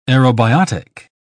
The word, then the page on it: aerobiotic: British English